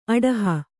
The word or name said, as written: ಅಡಹು